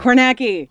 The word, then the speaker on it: Kornacki: Carnegie. No, not Carnegie. – Steve Kornacki. – Kornacki.